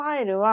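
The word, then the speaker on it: aisle: そう、 S はね、発音しないんです。これ、抜け落ちてる S で、うん、この I、I をって言うんだけど、うん、このアイルは、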